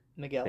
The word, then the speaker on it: Miguel: Is the U silent? – Miguel de Cervantes, right? – Right. – That's it. – The U is silent. Miguel.